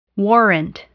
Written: warrant

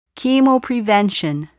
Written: chemoprevention

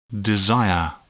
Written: desire

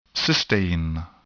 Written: cysteine

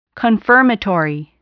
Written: confirmatory

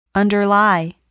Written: underlie

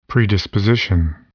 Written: predisposition

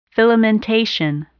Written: filamentation

